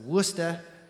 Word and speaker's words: Worcester: How do you pronounce that? Worcester. My Boston accent is not very good. Worcester. It's spelled Worcester. Why they say Worcester, I have no idea.